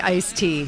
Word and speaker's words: iced tea: But it's technically iced tea. Now, the Merriam-Webster Dictionary says both pronunciations are fine. But the thing is, we leave the D out because we're, well, lazy. Ice cream also used to originally be iced cream, but they say it takes extra effort to do the enunciating of both those syllables.